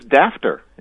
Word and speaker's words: daughter: And I guess it doesn't surprise me to find out with the GH in there that daughter used to be pronounced something other than daughter. – Yeah, it was dafter.